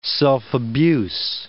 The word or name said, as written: self-abuse